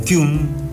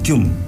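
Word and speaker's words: Kühn: Este muchacho ya un poquitín crecido, el hombre Joaquín Kahn, es un, o Kuhn, como se pronuncia, es un apellido alemán, Kuhn, porque tiene la diéresis sobre la U, ¿no?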